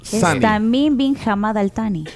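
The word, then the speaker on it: Al-Thani: Mira, pero se equivocó también porque dijo eh, Tamim bin Hamad al-Sani y no es Tani. – ¿O cómo se dice? – Eh, se dice... – Al-Tani. O sea, nosotros Al-Tani.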